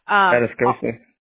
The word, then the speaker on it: Atascosa: So you have Wilson County, San Patricio County, Jim Wells County, Atascosa, I probably said that wrong. – Atascosa. – Yeah, there you go.